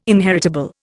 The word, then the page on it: inheritable: Indian